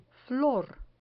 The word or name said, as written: flor